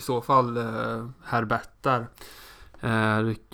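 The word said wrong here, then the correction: Pierre-Hugues Herbert: Pierre Hugues Herbert om jag får uh, ta fram Pierre min lilla franska här.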